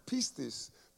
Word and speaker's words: pistis: And this word comes from the noun pistis, P-I-S-T-I-S, pistis, which means faith, faith, pistis, is faith.